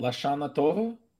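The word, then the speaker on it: L'Shana Tova: Is it La Shana Tova? – That is totally correct. That is a proper pronunciation.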